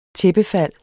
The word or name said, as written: tæppefald